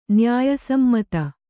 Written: ನ್ಯಾಯಸಮ್ಮತ

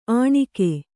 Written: ಅಣಿಗೆ

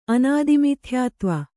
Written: ಅನಾದಿಮಿಥ್ಯಾತ್ವ

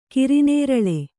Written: ಕರಿನೇರಳೆ

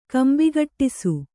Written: ಕಂಬಿಗಟ್ಟಿಸು

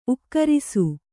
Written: ಊಗುರಿಸು

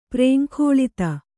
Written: ಪ್ರೇಂಖೋಲಿತ

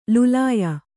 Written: ಲುಲಾಯ

